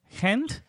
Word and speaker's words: Gent: And then we have the Europa League, the first leg again in the Olympico against Gent from Belgium.